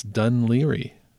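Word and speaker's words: Dun Laoghaire: So I didn't know how to pronounce D-U-N. That one seems kind of obvious, I guess. L-A-O-G-H-A-I-R-E. L-A-O-G-H-A-I-R-E. I know, wrap your head around that. And apparently, Regina says it's Dunleary.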